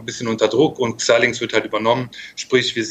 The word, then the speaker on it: Xilinx: Also Xilinx wird es ausgesprochen, gut.